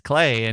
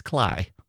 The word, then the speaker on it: Klay: but I think I can handle K-L-A-Y as Clay. And he said, well, actually, it's Cly.